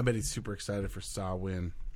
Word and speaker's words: Samhain: You know what's funny is this is like the only week of the year that I remember that it's pronounced Samhain. The rest of them I'm like, yeah, Samhain's coming around.